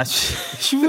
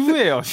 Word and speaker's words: 휴: 아, 쉬. 휴예요.